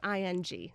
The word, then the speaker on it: Ng: It's just like ing.